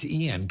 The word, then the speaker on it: Iyan: Yeah, I misread it the first time, and it's Ian because it's spelled I-Y-A-N. Ian.